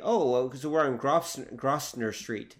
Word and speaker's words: Grosvenor: We have a Grosvenor Street in London. The way you pronounce it, like everyone who comes to London, it's Grosvenor Street. It's like, no, it's pronounced Grosvenor Street.